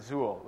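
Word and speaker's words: XUL: But anyway, in the end, you can pronounce it Zool, which I think is really the point of the whole exercise.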